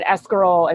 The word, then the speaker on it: escarole: Like, my great grandma would make, I'll say the way you're supposed, the proper pronunciation, escarole and meatballs.